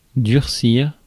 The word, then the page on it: durcir: /dyʁ.siʁ/; France